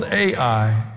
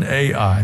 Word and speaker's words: Ai: It's called Ai. It's spelled Ai. Joshua, son of Nun, Ai.